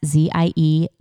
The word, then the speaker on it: Ziegenfuss: Her name is spelled K-R-I-S-T-A-N, and her last name is Dooley, D-O-O-L-E-Y. So follow Kristan Dooley, and then follow me as well if you don't yet. Becky Ziegenfuss, and that's Ziegenfuss.